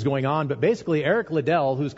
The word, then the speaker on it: Liddell: So probably most of you are familiar with Eric Liddell, or Eric Little as I always pronounced it.